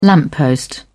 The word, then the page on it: lamppost: British English